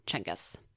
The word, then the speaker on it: Genghis: Technically, Genghis or Genghis are both correct, but in terms of what it would sound like from a Mongolian at that time, it would be probably more correct to say Genghis. – Genghis. – Yeah. – Genghis or Cheng? – In my head, I had the pronunciation of the game Jenga. – Yes. That's exactly Genghis what Khan. I was thinking. It was like Jenga.